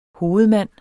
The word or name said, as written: hovedmand